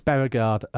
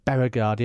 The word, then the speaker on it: Bjerregaard: Lucas Bjerregaard, the Dane, Mike's pick, half a point each way. Bjerregaard, yeah, I think the J is silent.